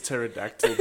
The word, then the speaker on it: pterodactyl: And the favorite one that Amy has introduced me to is pterodactyl because it has a silent P. – The P is silent.